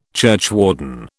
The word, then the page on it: churchwarden: British English